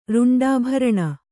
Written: ರುಂಡಾಭರಣ

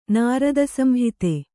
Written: ನಾರದಸಂಹಿತೆ

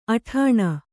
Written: ಅಟ್ಟಣೆ

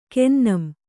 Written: ಕೆನ್ನಂ

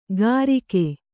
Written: ಕರಿಕೆ

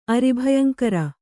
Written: ಅರಿಭಯಂಕರ

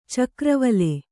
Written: ಚಕ್ರವಾಲ

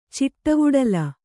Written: ಚಿಟ್ಟವುಡಲ

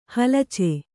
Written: ಹಲಚೆ

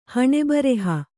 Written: ಹಣೆಬರಹ

